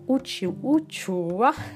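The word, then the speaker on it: uchuva: Pero bueno, al final todos nos reíamos con palabras como uchu, ah, tan difícil de pronunciar para un francés, porque la u para ellos no tiene el mismo sonido, es la u, uchua.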